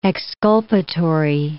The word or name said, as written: exculpatory